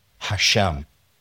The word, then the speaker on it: Hashem: How do you say it in Hebrew? Hashem. Hashem.